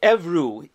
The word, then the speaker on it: Evreux: Um, but really, in, in French, it's Evreux, E V R E U X. Evreux is a uh, city in northern France, about 100 kilometers west of Paris.